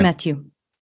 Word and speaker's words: Matiu: Matiu.